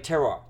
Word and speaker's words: terroir: Like terroir, right? – Yeah. – How do you pronounce it? – Terroir.